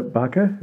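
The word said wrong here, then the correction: Bakke: is, I'm not sure how you pronounce her name. – It's Baki, but I don't.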